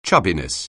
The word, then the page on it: chubbiness: anglais